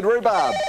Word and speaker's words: rhubarb: So which consonant is silent in the word rhubarb? Billy. – H. Robert Walls. – H it is.